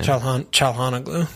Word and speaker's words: Çalhanoğlu: How do you say his name? – Çalhanoğlu.